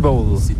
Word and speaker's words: bowl: Ça prononce comme bowl, mais c'est bowl. – Bowl, mais c'est pas comme un bol. – C'est B-O-W-L.